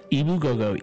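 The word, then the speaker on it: Ebu Gogo: It's just phonetically spelled the same way? – Ibu Gogo. E-B-U space Gogo.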